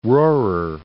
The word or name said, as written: Rohrer